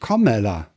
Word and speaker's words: Kamala: Kamala Harris, so Kamala Harris mit U vorne aussprechen, bitte. – Äh, mit O denke ich, Kamala. Kamala, genau, Kamala. Ja, ja.